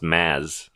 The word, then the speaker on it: Mads: You know, I learned it's pronounced Maz, no D. – Really? – I mean, it has a D, but the D is silent.